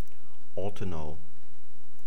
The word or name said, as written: ortenau